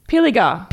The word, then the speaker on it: Pilliger: Pilger equals town in New South Wales. – Pilliger. – Pilliger. Pilliger. That's how I pronounce it.